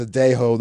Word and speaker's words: Sadejo: Yeah, y'all's boys, Sadejo, the lefty. That's how you say his name, right? – Like, uh, yes.